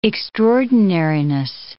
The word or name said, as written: extraordinariness